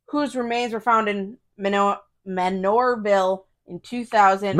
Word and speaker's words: Manorville: Manure. – Manorville. Did I say? I didn't, whatever. – No, it sounded like manure. – Oh. – Manureville is how you pronounced it. So just say manure.